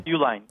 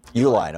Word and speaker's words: Uihlein: Illinois industrialist Richard Uihlein. Is it how it's pronounced? He's the yeah, guy who Uihlein. – Uihlein. Okay, he's the guy who backs the Liberty Justice Center, which represents Mark Janus.